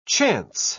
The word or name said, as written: chance